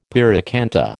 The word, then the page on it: pyracantha: American English